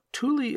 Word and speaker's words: Thule: Now, as a side note, Thule is spelled T-H-U-L-E, which I would normally say as Thal or something like that. But the interweb says to say Thule, so that's what I'm going with.